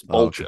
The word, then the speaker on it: Boltjes: How do you say your last name? Bojangles? – That's very close, actually. It's Boltjes.